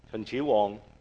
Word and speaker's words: 秦始皇: Qin Shi Huang，秦始皇。